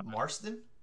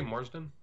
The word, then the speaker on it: Marsden: How do you say his last name? Marston? – I say Marsden.